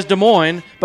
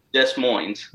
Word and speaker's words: Des Moines: Ooh, he attended the University of Kentucky, which possibly explains why he fully pronounces both S's when he says Des Moines, but can't seem to find the single S when he pronounces Louisville.